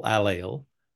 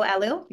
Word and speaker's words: allele: It to me, it doesn't make evolutionary sense that those people have carried on a 677T double allele. Did I say that correctly? – You did. Double allele.